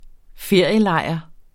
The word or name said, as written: ferielejr